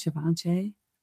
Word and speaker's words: Givenchy: Givenchy? – Yep.